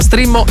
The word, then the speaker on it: Streamo: che si pronuncia Streamo, si scrive Streammo, e vi dà la possibilità di risentire le puntate della nostra Dance Chart.